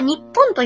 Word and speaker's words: 日本: 本当は日本と読むんですよ。 – あ、そうですよね。 – 日本。なんか日本人はすぐに、短くするのが好きらしくて。 – めっちゃ好きよね。 – うん。とにかく忙しい人種なんだって。 – へぇー。んで、うん、日本も日本っていう略語になってしまって。